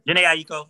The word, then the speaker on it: Jhené Aiko: Jhené Aiko.